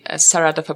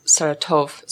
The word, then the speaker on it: Saratov: And some of you might be familiar with the movie Saratov Approach. Majority of you pronounce it as Saratov, so maybe that's more familiar. But Saratov is a city of about 1 million, and it's on the River Volga, longest river in Europe.